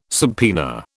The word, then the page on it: subpoena: British English